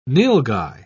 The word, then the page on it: nilgai: British English